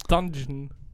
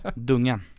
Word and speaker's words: Dungen: Dungeon. Fast ja. – Ja, fel. kanske. Dungen. Vi, dungen. vi säger dungen.